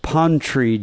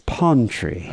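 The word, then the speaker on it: Pontrey: Pontree Jones. It's like the posh pronunciation of pantry. It's Pontree.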